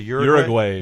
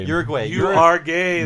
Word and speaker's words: Uruguay: How do you say the name of the country? – Uruguay. – You are gay. That's how Homer pronounces it.